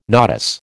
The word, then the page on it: nodus: American English